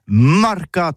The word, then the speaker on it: marcat: Eh bien, d'ici à tous, le mercat de l'Uni ou le mercat des rebelles, mais en Gascogne toulousain, c'est prononcé sur les marcats, avec un A marcat.